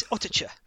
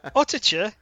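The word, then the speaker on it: Uttoxeter: It's Ottercher. Ottercher is like the most fun name on there to pronounce for me.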